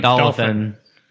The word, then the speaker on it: dolphin: Two syllables. Dolphin. – Dolphin. – A dolphin. But that sounds like a... – Dolphin. – Like a doll and fin. I always thought it was dolophin. – That's better than dolophin. – No, it's dolphin.